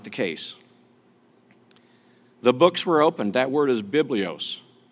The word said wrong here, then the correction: biblion: Biblion, I should pronounce that correctly. The word is biblion.